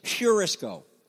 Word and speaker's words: heurisko: That means that the word has to be pronounced like an H. If it's going the other way, then there's no affectation at all. So this is heurisko.